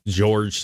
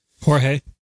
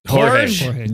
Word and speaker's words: Jorge: Okay, I don't know the guy, George something or other. – Jorge. – Sure, whatever. – That's how you say it in Spanish. – Jorge.